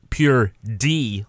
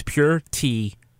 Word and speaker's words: pure D: So it's pure D, like David. – Yeah. – But it's been corrupted the way people say it. They say it like with a T. So it's pure T filth.